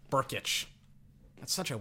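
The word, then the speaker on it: Brkić: Gabe Burkich. It's spelled B R K I C. I I I wouldn't I was like Gabe Brick? I thought the K was silent.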